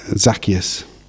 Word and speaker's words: Zacchaeus: And you, later on you had a story about Zacchaeus.